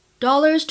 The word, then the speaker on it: dollars: Dollar 后面加 s 的话呢，发的是 z 的音，而 donuts 在末尾加上 s 会形成 ts 的发音。 Dollars to donuts，注意区别。